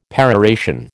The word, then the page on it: peroration: American English